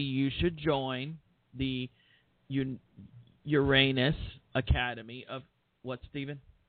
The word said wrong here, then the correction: Unarius: Oh, I'm mispronouncing the name. I'm sorry. The Unarius Academy of Science, which, oh my God.